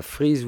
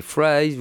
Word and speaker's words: fries: C'était pas freeze with that, mais fries with that.